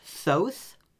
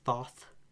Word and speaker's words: Thoth: Uh, so, the first one I picked is, from what I looked up, it's pronounced Thoth. – Okay. – Uh, like like oath with a th in front, but I've also heard him referred to as Thoth.